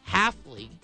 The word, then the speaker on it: Hafley: Hafley. H-A-F-L-E-Y. – Jeff Hafley. Kind of rhymes with Van Nunley.